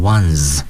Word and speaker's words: ones: 由于它用的是复数形式 buses，所以 the one 在这里也要加 s，但要注意 one 加 s 以后读成 ones 啊，由于它是那个浊辅音结尾，所以发音的时候呢要读成浊辅音 z。